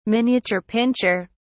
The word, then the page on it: miniature pinscher: British English